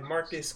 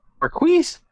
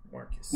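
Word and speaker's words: Marqus: I'm gonna go the or no, it's the, I think, by Marcus Clay. It's spelled MarquesClay. CLAE. – Marquise? – Marcus.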